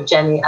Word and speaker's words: Gennye: So Gennye, as my name is spelled, G-E-N-N-Y-E, lion.love.